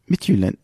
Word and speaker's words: Midtjylland: Also, seine Zahlen, da wo er gespielt ist, jetzt kommt's, du kannst den Verein aussprechen, ich nicht. FC Midtjylland. Midtjylland. Ja.